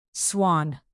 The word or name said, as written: swan